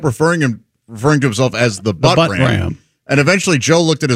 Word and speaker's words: Buttram: But he just kept the guy himself just kept saying this is the Butt Ram. – The Butt Ram is how he pronounced it, and that's his name.